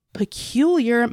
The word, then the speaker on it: Peculiar: We are coming to you from the home office this month in Peculiar, Missouri. Yes, I said that correctly. It's not a mistake. – No, it's definitely Peculiar, Missouri, and I've never been there, but I kind of want to visit.